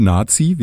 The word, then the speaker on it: Nati: Also Nati geschrieben, aber ich glaube Nazi wie Nationalmannschaft ausgesprochen.